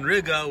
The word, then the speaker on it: Riga: I was so close to telling him Old Town Riga, which rhymes with nigga, so close to saying that.